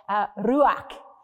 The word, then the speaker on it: ruach: Pneuma in the Hebrew is a word ruach, or if you want to pronounce it properly, ruach.